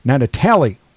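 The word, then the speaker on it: Gnatalie: The dinosaur is nicknamed Natatali, with a silent G for the gnats that swarmed the crew during the excavation back in 2007, found in the Badlands of Utah.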